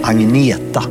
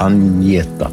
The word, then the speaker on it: Agneta: It is pronounced Agneta, not Agneta. So it's A-G-N-E-T-A.